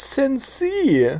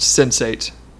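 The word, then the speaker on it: Sense8: I thought it was pronounced sensei. I feel like an ass. No. – Yes. It is pronounced sensate.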